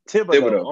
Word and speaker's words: Thibodeau: Thibodeau.